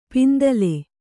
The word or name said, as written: ಪಿಂತಿಲ್